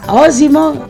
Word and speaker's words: Osimo: Eccoci qua, nuovamente in diretta dallo studio di Rimini, ma in diretta telefonica da Osimo, ho detto bene? – Hai detto bene.